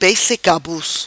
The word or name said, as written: Peixe cabuz